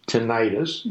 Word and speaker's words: tinnitus: but let's talk about tinnitus. Some people are going, tinnitus, what the heck is that? It's pronounced sometimes tinnitus.